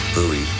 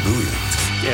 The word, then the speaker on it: Bowie: Bowie. I always thought it was Bowie. I thought, well, it's a Scottish name, must be Bowie. But nobody in Scotland pronounces it like that. They pronounce it Bowie.